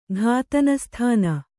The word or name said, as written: ಘಾತನಸ್ಥಾನ